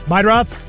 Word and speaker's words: Meidroth: Chase Murdaugh? Murdaugh? The White Sox guy? – Meidroth. – How do you say that? – Meidroth.